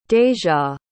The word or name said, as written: deja